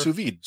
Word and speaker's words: sous vide: Oh, sous vide. – Sous vide. – Sous vide, yeah.